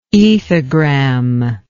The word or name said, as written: ethogram